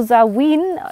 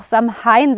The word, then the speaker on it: Samhain: Samhain, das wird auch Samhain sehr oft ausgesprochen, fälschlicherweise ist ein Jahreskreisfest, ein.